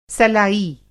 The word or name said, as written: सलाई